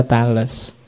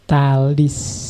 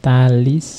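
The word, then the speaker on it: Thales: Ya, kalau bahasa Inggris juga gitu, kalau pakai dialek Inggris kadang memang dibaca Tales. Tapi pakai lidah Yunani sebenarnya bacanya Talis. Iya, Talis of Miletus. Meskipun tulisannya Miletus, kalau pakai lidah Inggris biasanya dibacanya Miletus. Tapi pakai lidah Yunani Miletus, jadi Talis of Miletus. Tapi ya kamu baca Tales juga enggak apa-apa.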